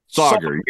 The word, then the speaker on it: Sauger: Is it Seeger? Am I saying that right? Am I saying his name right? – Sauger. Yeah. When he first got hired, they're like, think of soccer when you say his name. – Sauger.